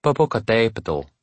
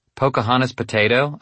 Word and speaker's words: Popocatépetl: She tried to get us to properly pronounce the volcano to the south of the city. "Popocatépetl," she said. "Pocahontas potato," I said.